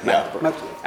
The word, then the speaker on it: Magdeburg: A couple of nights ago, we were in Mandelburg. Is that how you say it? – Magdeburg. – Magdeburg.